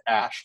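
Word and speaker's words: Æ: But yeah, the X, it's the X, and then that AE mashup thing is, he said, is pronounced Ash.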